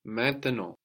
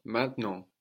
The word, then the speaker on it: maintenant: Now in French is maintenant or maintenant. So you can hear both.